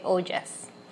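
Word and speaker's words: ojas: Así que para aclarar se escribe O-J-A-S y se dice ollas.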